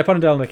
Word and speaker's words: Ponedelnik: Can you tell us a bit, please, about Viktor Ponedelnik? Have I pronounced that correctly? – Yeah, Ponedelnik, yeah, which means Wednesday in Russian.